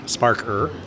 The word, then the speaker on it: Sparkr: So it's called Sparker, and it's spelled S-P-A-R-K-R, Sparker.